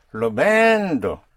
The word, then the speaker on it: lo vendo: Lo vendo. Yes. That push is important. Again, that down push on the syllable before last is a signal to Spanish ears that you're in the present tense.